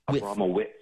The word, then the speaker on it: Abramowicz: There's no witch. It's a witch. Abramowitz.